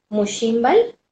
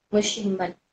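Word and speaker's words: Muuch Xíinbal: Otra, otro colectivo de mayas muy importante que, tiene un, muy buen manejo de sus redes sociales es Mushimbal. Este, se escribe M-W-C-H, Much, X, I, N, Shimbal, B-A-L, Mushimbal.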